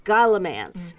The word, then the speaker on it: Scholomance: And there you came in and said, oh, the latest in the Golden Enclaves series, Scholomance. Is that how you pronounce that? – I think Scholomance. – Scholomance. Mm-hmm. So this series... S-C-H-O-L-O-M-A-N-C-E.